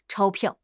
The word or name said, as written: 钞票